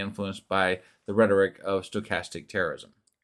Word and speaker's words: stochastic: Okay, go ahead and read that definition and tell me how to pronounce it. – So it's stochastic is what you were looking for.